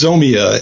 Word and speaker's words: Zomia: Well, first off, tell me about Zamia Offline Games, what that means. – Yeah, it's pronounced Zomia. And Zomia.